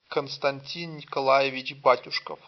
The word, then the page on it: Константи́н Никола́евич Ба́тюшков: [kənstɐnʲˈtʲin nʲɪkɐˈla(j)ɪvʲɪdʑ ˈbatʲʊʂkəf]; Russian